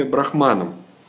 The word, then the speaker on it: Брахман: Брахман пишется с большой буквы, ударение на последнем слоге заканчивается на букву «э».